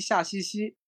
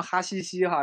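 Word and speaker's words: Haghighi: 然后那个有个比较有个比较神经刀的导演叫那个曼尼夏西西。啊，其实应该读成哈西西哈，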